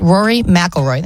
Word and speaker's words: McIlroy: So they were talking to pro golfer Rory McIlroy. Did I say that right? Rory McIlroy.